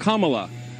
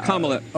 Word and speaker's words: Kamala: Her name is pronounced comma, like the punctuation mark. Look, Kamala. Okay? – Okay. – Seriously, I've heard every sort of bastardization. – So what? – That's how it is, Kamala.